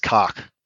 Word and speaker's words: Koch: So I think it in German, it actually is pronounced Koch.